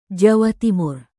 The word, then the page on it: jawa timur: Indonesian